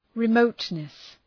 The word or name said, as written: remoteness